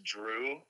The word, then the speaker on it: Jrue: And I think it's kind of funny that it's Drew. It's the same exact pronunciation, but it's a completely different name. – J-R-U-E. – Completely different cultural background to the name, everything, but it's the same urban. Exact name.